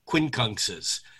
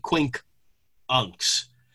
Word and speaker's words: quincunx: And for those of you who know my work on quincunxes, or any astrologer's work for that matter, first of all, we say it wrong. I say it wrong too. We all say quincunx. It's actually quinkunx, because unx is 12 and quink is 5, and a quinkunx is 5/12 of a circle.